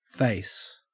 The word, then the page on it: face: Australian English